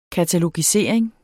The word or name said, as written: katalogisering